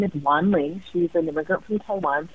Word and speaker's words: Wan Ling: And people would say things like, to Wingling or Wanglong, mm-hmm. or mm-hmm. one time I even remember Wendy, and it was just like pretty horrifying and shocking that people wouldn't even try to pronounce it even phonetically, like the, the letters they were using were all wrong.